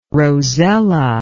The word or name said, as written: rosella